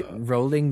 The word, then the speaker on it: Rowling: Can you just say Rowling this time, like bowling? – He wasn't the only one.